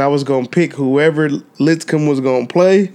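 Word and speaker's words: Lipscomb: We got North Carolina, Dorian's team playing Litz Cup. Did I say that right? – Litzcomb.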